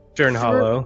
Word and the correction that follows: Burn Hollow: I believe it's pronounced Burn Hollow. – Burn? – We went through Burn Hollow, and then we were crossing the mountains to get down to Esterholt, and that's where we're in the mountains.